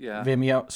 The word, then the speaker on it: Vimeo: Vimeo.